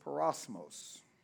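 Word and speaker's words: peirasmos: The Greek word for temptation is parosmos. Parosmos. You want to spell it? Take a note. It's P-E-I-R-A-S-M-O-S.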